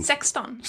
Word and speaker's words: sexton: How do you say that? 16. – Sexton.